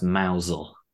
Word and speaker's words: Mousehole: There is a place called Mousehole that's pronounced Mousel.